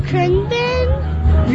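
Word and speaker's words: Khruangbin: I think I'm saying it right. Krungbin. Is that right? Krungbin? – What's the name of this band again? – Krungbin. – You got it.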